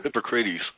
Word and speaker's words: Hippocrates: So does anybody kind of remember I'm probably going to pronounce his name wrong, Hippocrates, and what the Hippocratic Oath was about, and what he had to say about medicine? – Oh, Hippocrates.